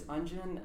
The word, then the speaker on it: Unjin: So my name is Unjin. I tell people that it rhymes with dungeon. That's an easy way to pronounce it.